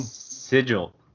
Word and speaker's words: Sigil: Seagull. For the blade. – Yeah, for the bottom. – Sigil. – Sigil. Did I say that wrong? Sorry about that. – Sigil, I think it's called the Sigil, yeah.